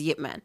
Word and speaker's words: Ip Man: Well, I only say Ip because that's how it's spelt, but it's actually pronounced as Yip Man.